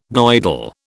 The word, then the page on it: Goidel: British English